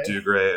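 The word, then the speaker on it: Dougray: Doug Ray.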